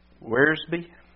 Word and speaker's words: Wiersbe: And Warren W. Wiersbe, I believe is the correct pronunciation of his name, says this: surely the good qualities of a successful fisherman would make for success in the difficult ministry of winning lost souls.